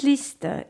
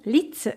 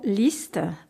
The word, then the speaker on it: Liszt: Les grands compositeurs s'en sont tous inspirés, et à commencer par le grand Franz Liszt, et non Liszt, comme prononcent les Français. Alors, pour bien dire son nom, Liszt, pensez donc à une liste d'achats.